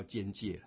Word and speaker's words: 尴尬: 因为那天我看新闻，居然尴尬那个字，教育部已经把它改成叫尖界了，真的，这是部班的发音，现在可以念作尖界，所以现在尖界不是念错了哈，是正确的，我们教育部颁发的那个发音。